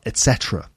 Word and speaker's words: etc.: What about etc., ETC, you know, blah, blah, blah, blah, blah, etc. Well, it should be pronounced etc., but you might hear people saying etc., like with a K. So it's not etc., should be pronounced etc. with a T.